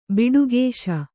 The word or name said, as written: ಬಿಡುಗೇಶ